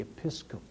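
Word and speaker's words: episkope: Now, this word for visitation in Greek is an interesting word, and you'll be able to tell a lot about it as soon as I pronounce it for you. Episcope, because it is the word from which we get episcopal.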